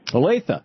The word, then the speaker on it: Olathe: Olathe, Kansas. Am I saying it right? – Olathe.